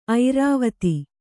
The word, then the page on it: ಐರಾವತ: Kannada